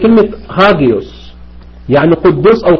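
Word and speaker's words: هاجيوس (ἅγιος): مش ده اللي احنا ايه مش ده اللي احنا ايه النطق القديم اللي انا اعرفه بيتنطق هاجيوس، الفا جاما يوتا اوميكرون سيجما.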